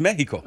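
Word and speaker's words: México: They don't pronounce the X in Spanish. What? – Yeah, it's Mexico.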